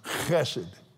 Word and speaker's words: chesed: It's actually pronounced chesed.